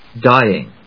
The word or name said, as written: dying